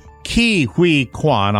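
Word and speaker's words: Ke Huy Quan: And we start today with, everybody was talking about the actors that both won, Brendan Fraser and Ki Huy Quan. – Yes, short round. – Ki Huy. Ki-Hui Kwan, I'll pronounce it correctly, wonderful, infectiously delightful human being.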